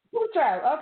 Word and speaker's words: Tatro: Tatro. – It's Tatro. Yeah. – Tatro.